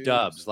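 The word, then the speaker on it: Doubs: maybe it's a Romeo Dubes. – You know, they're high on that kid. Everybody's. – I think it's pronounced Dubs, like a la sitting on Dubs.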